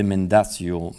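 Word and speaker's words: Mendacio: notamment le De Mendatio, Mendatio, mendatio le Contra Mendatium, euh, comme on le prononce en italien,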